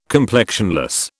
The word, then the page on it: complexionless: British English